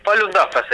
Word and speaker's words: Falun Dafa: Eh, Falun Dafa se dice.